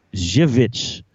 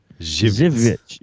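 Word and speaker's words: Żywiec: Zwix. – Zwix. – Zwix. It's Z-Y-W-I-E-C. It's Polish. Poland's.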